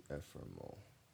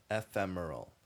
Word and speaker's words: ephemeral: How do you say that? ephemeral. Yeah, it's ephemeral.